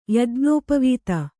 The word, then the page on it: ಯಜ್ಞೋಪವೀತ: Kannada